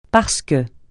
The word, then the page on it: parce que: francais